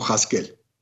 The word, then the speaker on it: Jasquel: a donde aparece una grafía ya distinta, que es J-A-S-Q-U-E-L, que en ambos casos se pronuncia como Haskel.